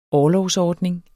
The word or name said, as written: orlovsordning